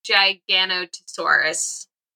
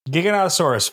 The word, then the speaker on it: Giganotosaurus: Grim Gigantosaurus. Gigantosaurus. Grim Gigantosaurus. – Giganotosaurus, folks. That's the way it works.